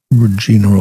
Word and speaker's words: Regina: First name is spelled R-E-G-I-N-A. I think it's Regina, rather than the other pronunciation that rhymes with a female personal part.